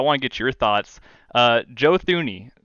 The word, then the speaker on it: Joe Thuney: The first thing I have to do, because it's been absolutely beaten into me these last couple of days, is that I have to change your Joe Thuney, because that's how it's pronounced, fair.